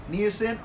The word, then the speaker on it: niacin: Niacin or niacin. I don't know if I'm pronouncing. – Ni- it Yeah, you're right. Niacin.